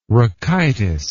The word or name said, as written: rachitis